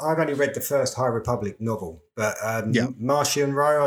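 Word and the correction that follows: Marchion Ro: I don't know if that's how you pronounce his name. – How you pronounce his name. It's Markion. – Markion Rowe.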